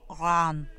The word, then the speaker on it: Reims: Y él estuvo es perteneciente a la orden, se escribe Reims, como la catedral y tal, y los franceses le dicen Reims,